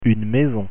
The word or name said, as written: maison